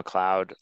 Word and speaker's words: McLeod: My name is Rorie McLeod.